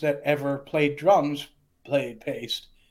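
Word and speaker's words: Paiste: And they said that their last name is Paste. Now, people say it both ways, you know. But in Europe, it's pronounced Paste.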